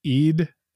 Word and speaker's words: Eid: We're introduced to an Eid, spelled E-I-D. It's pronounced Eid, which is a 3-day festival that celebrates the end of Ramadan.